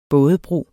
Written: bådebro

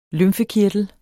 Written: lymfekirtel

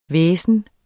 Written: hvæsen

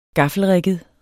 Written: gaffelrigget

